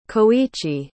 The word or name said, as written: koichi